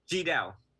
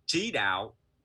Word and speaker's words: Chi Dao: So, I mean, if you were to pronounce it the Chinese way, right? It's Chi Dao, Chi Dao, Chi Dao. – But in American way, you know, it's kind of like CHI, so the Chi Dao.